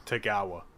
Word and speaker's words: Tagawa: And then it's just Tagawa as it's spelled. – Is it Tagawa or Tagawa? – Well, both versions seem to be said by Japanese commentators. So it seems to be a pronunciation. I've most commonly heard it as Tagawa. – Tagawa, like Kagawa, Shinji Kagawa.